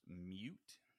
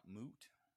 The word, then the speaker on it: mute: It's pronounced mute, not moot.